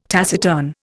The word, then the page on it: taciturn: Indian